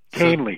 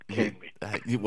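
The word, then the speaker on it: Kahnle: Kainley. So, I actually looked up the pronunciation this morning before we did this, right? Kainley.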